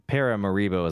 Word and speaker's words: Paramaribo: Yeah, well, okay, so this capital split, it's pronounced like it's Paramaribo is how I'd pronounce it. He pronounced it very differently.